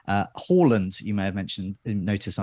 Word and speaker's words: Haaland: Now with Haaland up top, there's points tallies to look at from game week one, game week two, teams to reveal, community team latest.